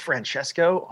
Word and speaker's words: Francesco: It's Francesco? Oh my gosh, I've been mispronouncing his name for like a year. That's terrible. Francesco, I am so sorry. I think I've been calling him Francisco Mortarino because I don't ever say it out loud.